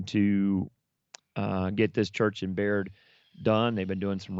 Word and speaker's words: Bayard: He's got a church that is an hour from here, that is called Baird. Now, if you look it up, you would look up Bayard, B-A-Y-A-R-D, but they pronounce it Baird, New Mexico.